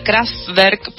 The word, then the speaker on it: Kraftwerk: Bueno, igual elegí esta banda que se pronunciaría Kraftwerk porque es en alemán,